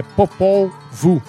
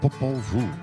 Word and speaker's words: Popol Vuh: A pronúncia é meio complicada, porque é o nome maia. É Popol Vuh. – Popol Vuh.